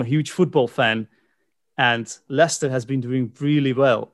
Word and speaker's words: Leicester: But if you read it, it looks like Leicester. But it's pronounced Leicester. It's one of those English words that we just try and fool everybody with. – Yeah. – By spelling it completely differently to how it's pronounced.